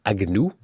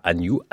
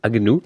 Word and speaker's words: Agnew: Ils seront sous la direction de Paul Agnou, c'est comme ça qu'on prononce, hein. pas Agnew, Agnou.